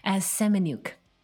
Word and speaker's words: Semeniuk: Semeniuk.